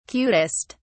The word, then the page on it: cutest: British English